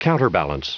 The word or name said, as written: counterbalance